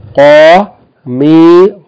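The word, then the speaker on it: qamisun (قَمِيصٌ): But please distinguish the qaf from ka and the sad from seen. This word should be pronounced qamisun, qamisun.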